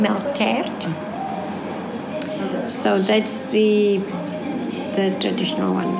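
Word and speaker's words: melktert: Other than that, we do the milk tart, which is a melt tart in Afrikaans.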